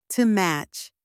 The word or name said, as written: to match